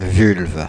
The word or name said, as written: vulve